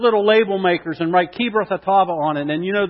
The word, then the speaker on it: Kibroth Hattaavah: Would you say that with me? Kibroth Hattaavah. Ready? Kibroth Hattaavah. Now say it like, you know, like a Middle Easterner.